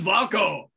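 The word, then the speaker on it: WAGO: Yeah, I mean, the thing is, like, when you mention it in a video and you say it wrong, there's always a German person who goes, no, it is Vargo.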